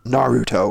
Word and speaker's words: Naruto: I thought it was Naruto, but I'll take your word for it. They probably say it on the show, which I've never watched. – Yeah, I said it wrong for the first 50 episodes. I was just like, I don't even remember how I said it, but it's Naruto, which I've been corrected multiple times.